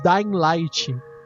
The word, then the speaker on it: Dying Light: Uh, o Daylight. O Dying Light. Melhor dizendo, a pronúncia nórdica correta.